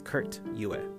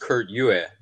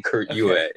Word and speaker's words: Kurt Yue: The funny thing is, it actually is pronounced Kurt Yue, but in for acting, I've been saying Kurt Yu. I joke that I use Kurt Yu as my stage name, and Kurt Yue is my real name, even though they're spelled the same way.